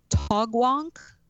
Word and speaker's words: Taugwank: Well, that seems to be where the, although I can't pronounce it, it's Taugwank. – Okay. – T-A-U-G-W-A-N-K. The Horace D. – Yeah, Minor. – You hit it pretty well.